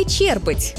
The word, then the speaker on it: черпать: Черпать воду или черпать? Вот тут многие спотыкаются. Правильный вариант – черпать, с ударением на первый слог.